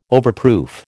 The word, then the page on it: overproof: American English